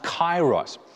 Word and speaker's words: kairos: But then there is another Greek word for time that we translate time, and it is pronounced kairos.